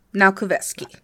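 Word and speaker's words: Naukowiecski: Now Kovetsky. – Yes, now Kovetsky.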